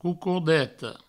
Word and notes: cocordeta: coucourdeto

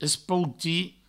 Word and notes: espoutir: provençal; espouti

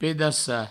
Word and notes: pedaçar: pedassa; provencal

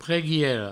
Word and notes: preguiera: preguiero; provençal

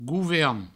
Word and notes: govern: gouver; Provençal

